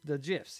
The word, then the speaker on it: GIF: Because there's like the GIFs, you know, they're like okay, 10 seconds. – Do you pronounce it GIF or JIF? – JIF. That's how the guy who created it pronounced it.